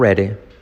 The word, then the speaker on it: ready: I'm not ready. I'm not ready. I'm not. La T casi no se dice. I'm not ready. La R genera una U. La D como una R suave.